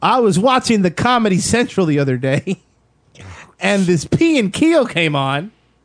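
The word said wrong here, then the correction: Key and Peele: Anyways, Key and Peel. – Yes. – Did I say it right? – Yes, yep. You got it.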